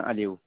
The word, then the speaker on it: Aliou: Je m'appelle Babojan Aliou.